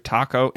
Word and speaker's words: Taco: I have to say, I think by far my favorite human character in this movie is Mr. Taco. And yes, you do say it like the food.